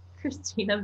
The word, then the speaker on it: Cristina: My Twitter handle is Christina Vanko, and that's CRISTINA. Everybody spells my name wrong. And well, I guess I should spell the whole thing out. Christina Vanko, CRISTINA VANKO.